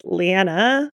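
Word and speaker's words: Leane: Leanne, who is pronounced Leanna, which was weird to me. – Yeah. I don't know, because there's only one N. It's Leanne.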